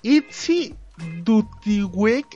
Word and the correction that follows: Itzy Dutkiewicz: Ay, perdón. Itzy Dutkiewicz.